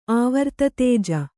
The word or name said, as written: ಆವರ್ತತೇಜ